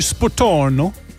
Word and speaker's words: Spotorno: Oh, vi skal til den italienske badeby Spotorno.